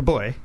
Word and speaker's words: buoy: Like Robin, like a boy. – B-U-O-Y. Okay. You know, it's actually pronounced... – Bowie. – It's not pronounced Bowie, it's pronounced boy.